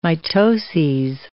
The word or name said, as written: mitoses